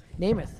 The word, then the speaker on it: Nemeth: Oh, so Nemeth, he took the E's and made them A's so everybody would pronounce it correctly.